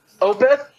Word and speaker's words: Opeth: Opeth, and then Lucana Coil. – Otep. Otep. O-T-E-P? – Yeah. Oh, no, O-P-E-T-H.